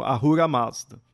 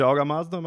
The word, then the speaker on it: Ahura Mazda: Ou Aura Mazda, ou você vai encontrar também como Ahura Mazda, porque tem um H. Eu tô acostumado a chamar de Aura Mazda, mas tanto faz você chamar, né? – Assim. Eu pronuncio H, pra mim é Ahura Mazda.